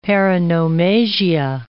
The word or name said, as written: paronomasia